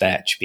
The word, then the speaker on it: Teach: Edward Teach. Which may or may not have actually been pronounced Thatch because, again, no standardized spelling.